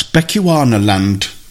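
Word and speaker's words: Bechuanaland: "Miss, how do you pronounce this?" And she looked at it and she said, "That's Bechuana land. It's now known as Botswana, which is a lot easier."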